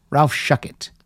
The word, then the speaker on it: Shuckett: Charlotte Caffey, Ellen Shipley, and Ralph Shuchek. – Okay. – Ralph Shuckett. That's what it says. Rhymes with fuck it.